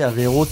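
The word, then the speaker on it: Avey Rode: Averode, ça s'écrit A-V-E-Y, on pense à Aveyron, voilà. – Même le R. Puis il y a Rode, pour route en anglais.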